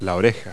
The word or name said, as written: ear